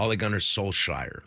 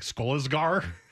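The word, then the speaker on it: Solskjær: But I never know how to pronounce his last name. – Ole Gunnar Solskjaer. – Solskjaer? Mm-hmm. I was like, Skolasgar?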